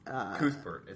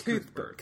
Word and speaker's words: Cuthbert: Cuthbert. It's Cuthbert.